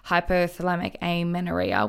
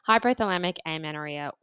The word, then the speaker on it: hypothalamic amenorrhea: And so one of those is hypothalamic amenorrhea, which I hope I'm pronouncing correctly. – You are.